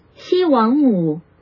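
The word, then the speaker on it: Xi Wangmu: It's three syllables. Three syllables. – Shi Wang Mu.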